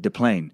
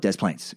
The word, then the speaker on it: Des Plaines: I thought it for sure would be like Des Plaines. But apparently, you know, the locals have decided to fuck the French pronunciation and call it Des Plaines, according to several videos I found.